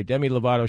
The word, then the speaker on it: Demi Lovato: Okay, Demi Lovato.